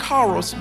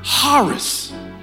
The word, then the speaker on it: charis: It's actually spelled with a ch. It should sound like charos, but it's pronounced charis.